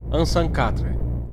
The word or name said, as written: Unsancatre